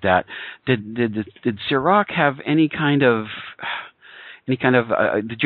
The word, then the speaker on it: Sirach: And, of course, we have to get to Sirach. Is that how that's pronounced? – Yes, yeah, Sirach, but yes.